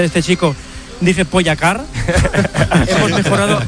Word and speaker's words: Pogačar: Juanma dice que es aguda, mientras que otros es drújula, así que aquí tenemos cómo se dice de verdad. – Tadej Pogačar. Tadej Pogačar. Tadej Pogačar.